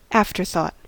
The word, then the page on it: afterthought: American English